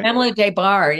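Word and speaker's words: Pamela Des Barres: Now, there's another character I want to talk about who, slightly different context, Pamela Desberes. Did I say that right? – Pamela Desbar, yeah. – Yep, Pamela Desbar.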